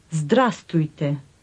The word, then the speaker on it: Здравствуйте: Здравствуйте.